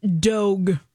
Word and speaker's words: Dogue: There is a restaurant in San Francisco called Dogue. – Ooh, how do you spell that? – D-O-G-U-E. – Oh. – Like Vogue. – Oh, but, I see. – But with a D.